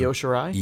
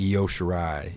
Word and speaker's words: Io Shirai: I never know how to pronounce that other girl's name. What's her name? The other one? – Io Shirai? – Io Shirai, yeah.